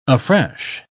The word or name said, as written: afresh